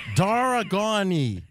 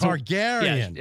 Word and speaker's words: Targaryen: Then we've got this fellow, Tyrannus Dargani.